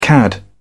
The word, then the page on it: cad: British English